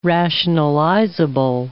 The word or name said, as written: rationalizable